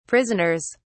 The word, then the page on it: prisoners: British English